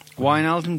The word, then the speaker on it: Wijnaldum: Wijnaldum. – I think is that good, Dave? Is that how you pronounce it? – Like Wijnaldum, but yeah. – Wijnaldum, do you think?